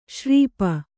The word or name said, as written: ಶೂರ್ಪ